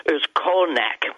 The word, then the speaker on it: Özkonak: One of the most amazing cities they found, the biggest city they found, is a place called Özkonak. I can spell that for you. O-Z-K-O-N-A-K.